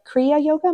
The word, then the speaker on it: Kriya Yoga: In 2001, you became a certified teacher of Hatha Yoga at the Temple of Kriya Yoga, am I saying that correctly? – You are, yes.